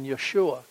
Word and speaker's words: Yeshua: As the character here said, our hope is in Yeshua. That's the Hebrew pronunciation of his name.